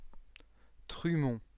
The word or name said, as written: trumont